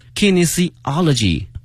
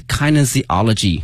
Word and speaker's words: kinesiology: Soon will be studying kinesiology 或是 kinesiology 啊，两个发音。